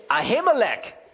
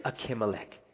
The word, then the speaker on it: Ahimelech: Someone brought that to my attention. They said, Hey, you're mispronouncing it. It's Ahimelech. Actually, it is Akimelech. It's not Ahimelech, it's Akimelech. In case you were wondering and you were probably thinking the same thing, hey, this guy keeps mispronouncing. The way you pronounce this in the Hebrew is Akimelech.